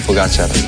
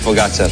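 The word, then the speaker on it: Pogačar: Juanma dice que es aguda, mientras que otros es drújula, así que aquí tenemos cómo se dice de verdad. – Tadej Pogacar. Tadej Pogacar. Tadej Pogacar.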